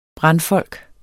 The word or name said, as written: brandfolk